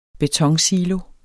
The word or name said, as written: betonsilo